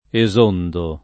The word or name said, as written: esondare